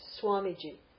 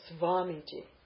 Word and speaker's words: Swamiji: So Swamiji, that's Swa, not Svamiji, right? But some people will say Svamiji.